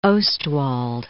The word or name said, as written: Ostwald